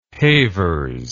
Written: havers

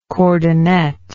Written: cordonnet

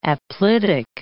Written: aplitic

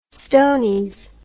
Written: stonies

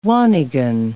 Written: wanigan

